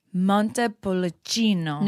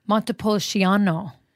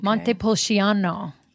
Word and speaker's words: Montepulciano: So today we're opening up a bottle of Montepulciano. – No, you said that wrong. Montepulciano. Okay. Montepulciano.